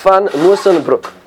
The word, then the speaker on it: Musschenbroek: That's not Van. That's Van Musschenbroek. Okay, that's how it's pronounced in Dutch.